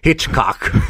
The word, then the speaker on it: Hitchcock: Jag älskar hur du uttalar det som kommer efter Hitch. Hitchcock. – Men man måste lägga betoning på rätt ord i ordet.